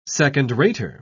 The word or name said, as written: second-rater